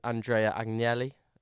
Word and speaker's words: Andrea Agnelli: Um, according to Andrea Agnelli, I think I'm saying that right.